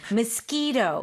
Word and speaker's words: mosquito: Mosquito. It does sound like a K.